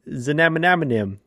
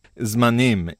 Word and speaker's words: Zmanim: You're both wrong. It's Zmanim. It's Zmanim. It's a Hebrew word.